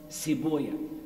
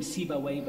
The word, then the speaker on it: Sibawayh: And there was a very famous scholar of the Arabic language whose name was Sibuya. Sometimes we call him Sibawayh, but that's an Arabic pronunciation. His real name was Sibuya.